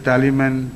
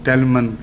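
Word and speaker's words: Tally Man: اور وہ گانا یہ تھا, اس کے ایک دو مصرے مجھے یاد ہیں کہ کم مسٹر ٹیلی مین ٹیلی می بنانا, یہ ان کے تلفظ میں ہے, ٹیلی مین کو ٹیلمن کہہ کے پڑھتے تھے, کم mister ٹیلی مین ٹیلی me banana, day light come and I want to go home.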